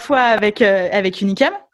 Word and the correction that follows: Unicum: comment tu le prononces Unicum. Unicum.